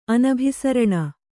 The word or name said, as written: ಅನಭಿಸರಣ